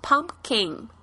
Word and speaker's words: pumpkin: 南瓜就是 pumpkin。我们特别注意一下这个 P 的发音，pumpkin 啊，不要发得太重，不要念成 pumpkin。